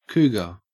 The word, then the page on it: cougar: Australian English